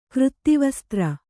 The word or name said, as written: ಕೃತ್ತಿವಸ್ತ್ರ